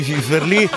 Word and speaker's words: finferli: In un mare di finferli, siate quelli con l'accento sulla seconda sillaba, va bene? Sulla seconda vocale, vabbè, quello che è.